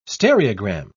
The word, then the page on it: stereogram: British English